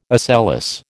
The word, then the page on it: ocellus: American English